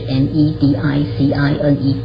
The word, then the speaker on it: medicine: 那你的断字的方法呢，就会是子加母为一家，所以呢就是 m e d i c i n e 各自为一家。呃 i space 一呢，字尾一是不发音的，所以字尾一不发音呢，当然你合起来之后呢，就会是念成 medicine，所以它就是三个家族，那就记起来 medicine 这个字。